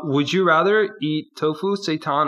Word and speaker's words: seitan: Seitan. It's like. – Seitan, that's it, yeah.